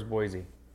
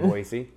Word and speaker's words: Boise: There's no Z in Boise.